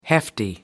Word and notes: hefty: American English

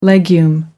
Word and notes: legume: American English

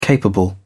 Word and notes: capable: British English

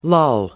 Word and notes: lull: American English

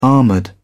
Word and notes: armored: British English

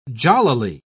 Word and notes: jollily: British English

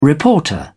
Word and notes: reporter: British English

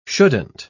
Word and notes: shouldn't: British English

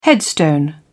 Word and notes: headstone: British English

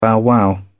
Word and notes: bow-wow: British English

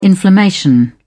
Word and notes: inflammation: British English

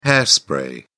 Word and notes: hair spray: British English

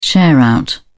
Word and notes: share-out: British English

